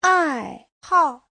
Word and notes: 爱好: ai4hao4

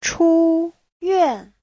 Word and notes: 出院: chu1yuan4

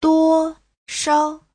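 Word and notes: duoshao: duo1shao5

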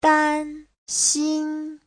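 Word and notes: danxin: dan1xin1

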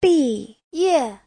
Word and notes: 毕业: bi4ye4

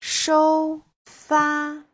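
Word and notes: shoufa: shou1fa1

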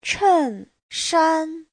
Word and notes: 衬衫: chen4shan1

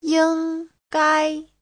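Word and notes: yinggai: ying1gai1

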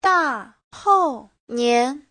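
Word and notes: dahounian: da4hou4nian2

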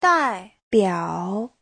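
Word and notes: daibiao: dai4biao3

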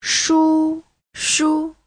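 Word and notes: shushu: shu1shu5

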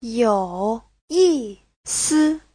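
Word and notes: youyisi: you3yi4si5

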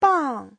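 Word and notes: 棒: bang4

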